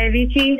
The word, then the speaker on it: Avicii: The song Wake Me Up by Avicii.